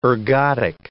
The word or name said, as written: ergotic